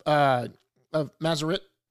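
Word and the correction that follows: Marisnick: How do you say it? – Jack Marisnik. I said Mazarek.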